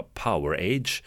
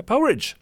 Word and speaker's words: Powerage: Som jag väldigt länge trodde man skulle säga uttala Powerage. Nu ska man inte, man ska säga Powerage, har jag lärt mig.